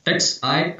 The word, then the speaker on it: Xi: Chairman Z, how do you pronounce his name? X-I. – X-I? It's Chairman Xi, yeah.